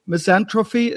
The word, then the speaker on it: misanthropy: misanthropy is that the way to pronounce it? Yes, yes, and yes.